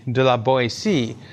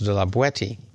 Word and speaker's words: de la Boétie: And if you know French, you probably think I'm mispronouncing that, but in contemporary French, it would be de la Boétie. But apparently it's thought that in his era and in the particular part of France he lived in, that would have been pronounced de la Boétie.